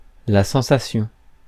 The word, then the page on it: feeling: /ˈfiː.lɪŋ/; [ˈfiː.lɪŋ]